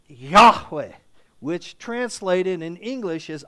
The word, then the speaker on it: Yahweh: And the aspect is that this is referring back to Yahweh, that term that we pronounce, Yahweh.